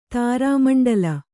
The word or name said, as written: ತಾರಾಮಂಡಲ